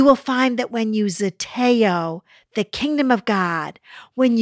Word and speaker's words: zeteo: Let me tell you what zeteo means. It's spelled Z-E-T-E-O.